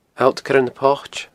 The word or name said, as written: Allt Coire na Poite